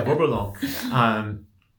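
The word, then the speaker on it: Bourboulenc: Bourboulonc. – Very close. Yeah. – Hey, Google. – Yeah, Bourboulonc.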